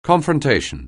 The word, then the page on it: confrontation: anglais